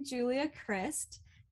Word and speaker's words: Julia Crist: So I'm Julia Crist.